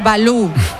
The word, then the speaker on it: Valou: Este Balú es con V, ¿eh? No es como el oso. – Sí, Yanis Balú. V-A-L-O-U. Creo que lo digo bien, ¿no? – Más vital.